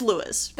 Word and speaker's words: Louis: No, but it's spelled like Louis instead of like Lewis. – Oh. – So I mean, looks like Lois. Okay. But it is Lewis.